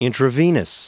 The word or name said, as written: intravenous